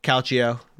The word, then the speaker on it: calcio: But I know Italian footy, or calcio, right? Is that how you pronounce it? – Yeah, exactly. Yeah, is fantastic to watch.